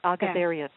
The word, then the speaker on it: Agazarian: I believe you called her Agazarian. Agazarian. – Agazarian. Yeah.